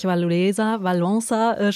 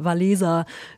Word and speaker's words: Wałęsa: Sie haben jetzt gerade den Solidarność-Gründer Lech Wałęsa schon mal angesprochen. Habe heute erst gelernt, dass er so ausgesprochen wird. Ich habe immer Wałęsa gehört, gelesen. Wird auch, glaube ich, oft hier in Deutschland so gesagt.